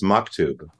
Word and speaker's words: Maktub: Anyway, you were living in Seattle during the height of indie music in the mid-90s, and you joined a band called Maktub. I'm saying it right, right? – It's Maktub. – Ooh. Yeah. Oh, it's Maktub. My apologies. Maktub. – No, it's all right. It's an Arabic word.